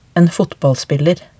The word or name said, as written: en fotballspiller